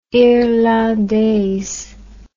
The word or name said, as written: irlandaise